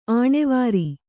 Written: ಅನುವರ

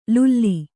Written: ಲುಲ್ಲಿ

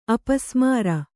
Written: ಅಪಸ್ಮಾರ

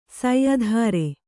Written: ಸೈಧಾರೆ

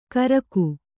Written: ಗರ್ಗ

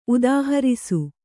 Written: ಉದಾಹರಿಸು

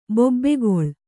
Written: ಬೊಬ್ಬೆಗೊಳ್

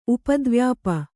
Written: ಉಪದ್ವ್ಯಾಪಿ

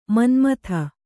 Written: ಮನುಮತ

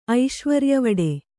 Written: ಐಶ್ವರ್ಯವಡೆ

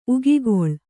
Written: ಉಗಿಗೊಳ್

